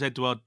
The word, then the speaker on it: Edouard: Okay, so Owen Hazen says, why was Edouard dropped? – That's how you pronounce that, is it? – Yeah.